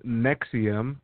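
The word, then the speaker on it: NXIVM: It's pronounced Nexium.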